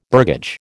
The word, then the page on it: burgage: American English